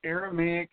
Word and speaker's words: Aramaic: And Roy is the founder of AVA, the Aramic. Oh, I'm saying that incorrectly. – Right. – Tell us what it is. – It's the Aramaic Vernacular Association.